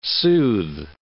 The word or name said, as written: soothe